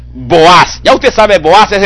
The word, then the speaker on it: Boaz: Cuando Salomón construyó el templo, escribió sobre las dos pilares principales, los nombres de Jaquín y Boaz, o Boaz, si usted lo quiere pronunciar en español, pero la pronunciación correcta es Boaz.